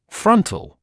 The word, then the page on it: frontal: ‘frʌntl; ‘франтл